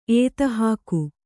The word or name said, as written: ಏತಹಾಕು